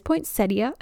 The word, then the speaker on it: poinsettia: Some people call it poinsettia, but there is the IA at the end. I always pronounce it wrong. It is poinsettia.